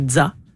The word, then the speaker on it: ZZA: Le groupe de jazz de Québec qui s'écrit ZZA. Donc, c'est Dza, comme la fin de pizza.